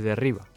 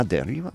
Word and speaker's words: derriba: How do you say that? – Se derriba. – Ah, derriba. Oh, dude, I wasn't so far. Just like one vowel away.